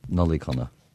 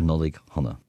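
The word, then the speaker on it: Nollaig Shona: Nollaig Chonaic. – Nollaig Chonaic. – Nollaig Chonaic. Hona is to you, nollaig is Christmas, so nollaig Hona is basically Happy Christmas.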